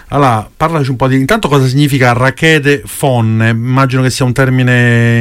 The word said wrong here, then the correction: radiche fonne: Sì, allora, la pronuncia corretta, perché giustamente magari dici no, non lo sappiamo. – Giustamente sarebbe radiche fonne.